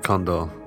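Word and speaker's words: Kondor: My name is Luke Condor, spelled with a K,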